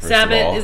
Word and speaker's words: Sabbat: Yeah, I'm doubling down on Sabbat, Sabbat is the way it's pronounced, first of all.